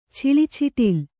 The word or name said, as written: ಛಿಳಿಛಿಟಿಲ್